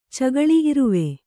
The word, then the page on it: ಚಕ್ಕಳೆ: Kannada